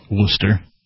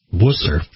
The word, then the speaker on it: Worcester: Worcester. – Worcester, perfect sure.